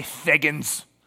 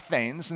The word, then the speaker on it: thegns: And you want to say thegns as, you know, an English reader, but it's pronounced thanes.